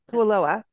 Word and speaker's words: Kualoa: And Kualoa, am I saying that right? I'm going to make sure. – Yes.